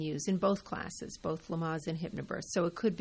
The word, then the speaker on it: Lamaze: Lamaze. – Mm-hmm. – Did I say that right? – Yep. – Lamaze? – Mm-hmm.